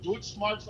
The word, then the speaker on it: Deutschmarks: Deutschmarks. I was like, what's Dutchmarks? You were like, no, you're saying it wrong, bro.